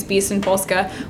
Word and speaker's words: Bison Polska: We're going to play one of his tunes, Beast in Polska, which he once told me, I think he wrote it after their first tour in America. It's spelled like Bison Polska.